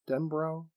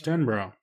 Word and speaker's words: Denbrow: How do you say his name? Denbrow. Denbrow. – Denbrow.